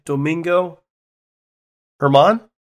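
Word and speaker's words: Germán: Domingo Herman? – Yep.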